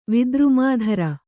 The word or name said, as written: ವಿದ್ರುಮಾಧರ